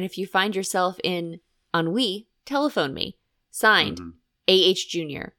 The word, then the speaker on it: ennui: How do you say E-N-N-U-I? – Ennui. – Ennui. I always say N-U-I. – Mm-mm. – Yeah. Ennui. – It's French.